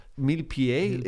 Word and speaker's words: Millepied: Millepied. – It's how he would pronounce it. – It's how he would pronounce it, but it's not how I'm pronouncing it. And he's not on this podcast. So, no, yeah. But it's millipede.